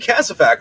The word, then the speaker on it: casevac: and he kept pronouncing it Casevac. And I was like, hey, man, why are you pronouncing this Casevac? And he's like, what is it? Oh, it's supposed to be Casvac.